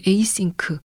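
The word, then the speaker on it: ASYNC: 2017년에 발표한 에이싱크, 스펠링 ASYNC라는 이 앨범에 대한 얘기 중에서 일부를 잠깐 들어보시죠.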